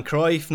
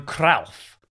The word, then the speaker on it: Cruyff: I'll just tell you how Simon Cooper pronounced it. He said Johan Cruyff, which heard before. I'm sticking with Cruyff myself, but just do carry on.